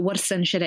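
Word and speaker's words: Warsan Shire: But Warsan Shiro is how we'd pronounce it.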